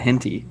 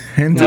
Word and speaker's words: hentai: Can you talk about that henty for a second? – That's that good stuff right there. – Wait, that's how you pronounce it? – Hentai? No, it's hentai.